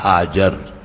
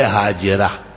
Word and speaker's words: حاجر: مشکات شریف یا بخاری پڑھنے والوں کے لیے تو عجوبہ نہیں ہے, وہاں تو صاف آتا ہے اخدمہا حاجر, لیکن یہاں آ کر کے پھر ہمارا تلفظ ہو جاتا ہے حاجرہ. حاجرہ دوپہر کو کہتے ہیں عربی میں. یہ موزمبی کی زبان ہے اور حضرت اسماعیل کی والدہ ام اسماعیل جیسے کہتے ہیں وہ حاجر تھی.